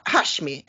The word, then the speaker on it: Hashmi: Jag tycker vi ska redan nu ta och titta på ett litet klipp med en intervju med frilansjournalisten Siraj Hashimi. Uttalar jag det rätt nu? Hashmi, ja just det.